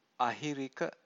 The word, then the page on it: ahirika: Pali